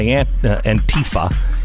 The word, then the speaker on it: Antifa: He has been in the hospital for a brain bleed because he was beaten by Antifa. Antifa. How do you say it, Stu? – Anti-fa. – Anti-fa.